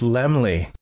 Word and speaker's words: Laemmle: How do you do, Mr. Carl Lim- Le- Oh, it's Laemmle. I was saying it wrong the whole time because hmm.